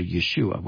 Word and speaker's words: Yeshua: Let's go to Yeshua, which is the name of Jesus, the Hebrew pronunciation.